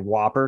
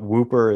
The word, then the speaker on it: Whooper: Some people call it the Dungarvan Whopper because it's spelled the same way as the burger, but Whooper is the right pronunciation.